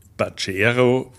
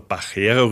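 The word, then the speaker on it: Pajero: Mitsubishi Pachero, wie man das bei uns sagt, oder Pachero richtig ausgesprochen.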